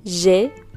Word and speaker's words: J'ai: Si se fijan ahí, yo estoy pronunciando E, una E abierta. Esto significa yo tengo. Si pronuncian je, es yo, ¿ok? J'ai, tu as, a, il, elle, a, il, elle, a, nous avons, vous avez, ils, elles ont, ils, elles ont.